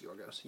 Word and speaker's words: Georgios: There's a guy who used to play for the Kings whose name was spelled like G-I-O. Yeah, it was spelled that way, pronounced Yorgos. Yorgos.